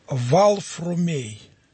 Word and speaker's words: Варфоломей: Так вот, когда он еще не был Сергием, а когда он был Варфоломеем, вот в то время на Руси имя Варфоломей не произносилось так, как произносится сейчас. Оно произносилось Валфрумей. И если бы в древности кто-то бы искал этого отрока, и он говорил, мне нужен Варфоломей, он бы не нашел его.